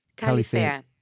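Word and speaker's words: Kallithea: It's K-A-L-L-I-T-H-E-A. Kalithea. Kalithea.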